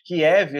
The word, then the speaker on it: Kiev: No iniciozinho da invasão, que foi o fantasma de Kiev, né? Falei certo? – Falou bonito, Kiev.